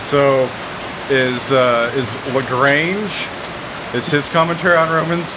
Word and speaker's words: Lagrange: And he just laughed at me and he goes, I know you're from Texas, but you have to give some sort of effort in pronouncing the French, like Lagrange.